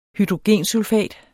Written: hydrogensulfat

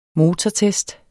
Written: motortest

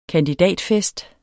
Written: KANDIDATENS

